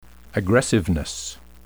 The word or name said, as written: aggressiveness